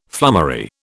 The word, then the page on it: flummery: British English